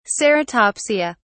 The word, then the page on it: ceratopsia: British English